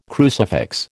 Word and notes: crucifix: American English

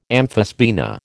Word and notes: amphisbaena: American English

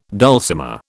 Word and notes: dulcimer: British English

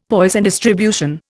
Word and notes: Poisson distribution: Indian

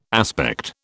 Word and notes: aspect: British English

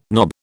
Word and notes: knob: British English

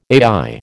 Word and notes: AI: American English

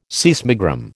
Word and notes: seismogram: American English

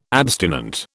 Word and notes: abstinent: British English

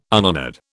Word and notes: unhonoured: British English

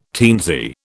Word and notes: teensy: British English

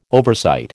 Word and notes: oversight: American English

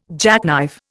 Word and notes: jackknife: Indian

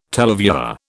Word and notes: televiewer: British English

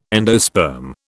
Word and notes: endosperm: British English

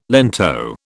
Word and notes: lento: British English

